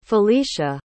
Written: filicia

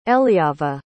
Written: eliava

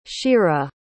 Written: shira

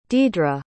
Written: deedra

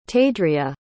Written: tadria

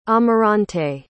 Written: amarante

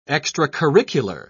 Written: extracurricular

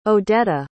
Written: odetta

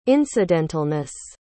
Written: incidentalness

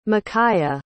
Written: micaiah

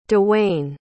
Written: dawayne

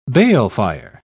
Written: balefire

